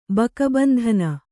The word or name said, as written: ಬಕಬಂಧನ